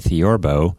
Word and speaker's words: theorbo: Yeah, it's a lot of people think it's maybe a huge bass guitar or something like that, but this is called a theorbo. It's spelled T-H-E-O-R-B-O, and it's a member of the lute family. So L-U-T-E, the lute family.